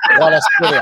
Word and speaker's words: Kvaratskhelia: Liam saying, is that really how you say it? Kvaratskhelia? – Kvaratskhelia. – How they say it in Naples.